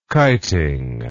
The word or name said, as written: kiting